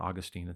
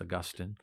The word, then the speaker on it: Augustine: That's how you say it. It's not Augustine. It's Augustine.